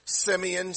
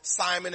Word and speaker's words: Simeon: And behold, there was a man in Jerusalem whose name was Simeon, some pronounce in Jewish or the Hebrew language, or Simon, as we would say.